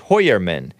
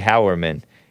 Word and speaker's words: Heuermann: Pronounced Hoyerman, but it looks like Howerman. There's no A in it, except for at the end. H-E-U-E-R-M-A-N-N.